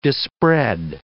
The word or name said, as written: dispread